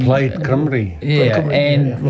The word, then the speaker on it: Plaid Cymru: Plaid Cymru.